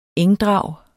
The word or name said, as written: ENGDRAGET